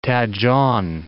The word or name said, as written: Taejon